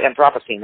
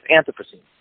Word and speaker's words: Anthropocene: I always say Anthropocene, and I can't break myself of it. It's Anthropocene.